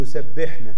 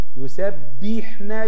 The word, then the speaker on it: يسبحن: أنا كل اللي أنا سامعهم بينطقوا كلمة يسبحن غلط. بيقولوا يسبحن. أيوة يسبحن بالعشي والإشراق. لا برضك لسه في ناس تقول يسبحنا ب تحتها كسرة. ب كسرة ايه؟